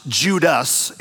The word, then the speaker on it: Judas: Now, Jude's name would have been pronounced Judas in Greek.